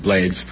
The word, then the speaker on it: Blades: It is Blades, it's not Blades, as so many people correct me, but that's how he pronounces it, Blades.